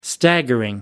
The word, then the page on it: staggering: American English